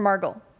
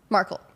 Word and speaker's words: Markle: Markle or Margle? Markle.